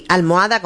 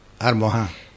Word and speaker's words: almohada: Las dos formas son correctas, es decir, almohada, como dijo el abuelo, o con la H aspirada. – Almohá. – La H aspirada es herencia de la pronunciación árabe almohá.